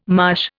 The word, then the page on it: marsh: Indian